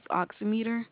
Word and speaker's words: oximeter: You said oximeter, there's not a Z in it. It's O-X-I. – Oximeter, O-X-I-M-E-T-E-R. – Right. – Oximeter, that's how that is pronounced.